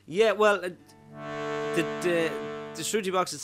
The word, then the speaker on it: shruti: Yeah, shruti box. – So how do you spell it? – S H R U T T I. – Right, shruti. – Yeah. – Shruti. As in tutti frutti shruti.